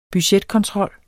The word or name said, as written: budgetkontrol